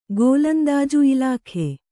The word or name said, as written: ಗೋಲಂದಾಜು ಇಲಾಖೆ